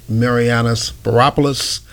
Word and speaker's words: Spiropoulos: Kirk Mariana Spiropoulos. – Rhymes with Metropolis.